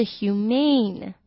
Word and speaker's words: humane: humane humane 这跟 humanity 的 A 的发音又不太一样了 humane 后面 M-A-N-E 是长音